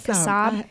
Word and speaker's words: Kassab: Doors open at 2 o'clock, 2:30 is the showtime, and we've got the Will McBride Group with Sound of Scarlet and Andrew Kassab. Am I pronouncing that right? Yeah. All right, Kassab. Wonderful musicians.